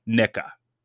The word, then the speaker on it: Knicca: In 94, the group added its fourth member, and this is spelled with its, I'm going to spell it before I say it, just to be clear. Koopsta, K-N-I-C-C-A, Nicka. Koopsta Nicka. I'm reading it as it's spelled.